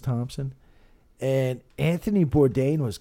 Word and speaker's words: Bourdain: How do you say his last name? Bourdain. – Bourdain. – Right? – Yeah.